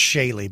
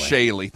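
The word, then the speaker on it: Shailagh: I think it's Shaley, by the way. – Shaley, thank you. It probably is. – I like Shalog better. – Shalog or Shaley.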